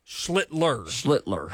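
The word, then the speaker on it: Schlittler: Cam Schlitter. – Schlitter. I beg your pardon. – I think I said that correctly, but I still had some doubt, too, whenever that came out of my mouth. – No, you're right. There is a second L in there, and I think you and I are both going to have our jobs in 10 minutes.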